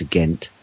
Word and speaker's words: Ghent: That's G-E-N-T, not G-H-E-N-T, as most people spell it in the English-speaking world, probably because if they saw the word spelt G-E-N-T, they would say Gent. – Gent. – And Gant. – It is actually pronounced Ghent, of course.